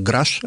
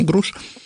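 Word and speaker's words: Grusch: По-моему, его фамилия все-таки произносится как Граш, а не Груш.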